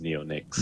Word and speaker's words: neonics: And you did pronounce it right. It is neonics.